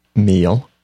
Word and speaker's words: meal: For example, meal. – Meal. – Yes, meal. – Like what you eat. – Yeah, not mill, like a sawmill.